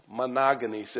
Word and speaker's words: monogenes: Monogenes. It's M-O-N-O, meaning one, isn't it? And then G-E-N-E-S, but it's pronounced monogenes.